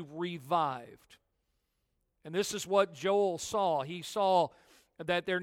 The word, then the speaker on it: Joel: Joel is really Ya'el. Joel, Ya'el is how you pronounce his name.